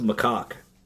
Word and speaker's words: macaque: It is pronounced macaque.